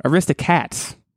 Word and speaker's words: Aristocats: Aristocrats. I just said it wrong. Aristocats. You want to say Aristocrats because that's the actual word.